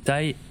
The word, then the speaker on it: Tigh: T-I-E, ils l'ont écrit comme le nom de famille du colonel Tai T-I-G-H.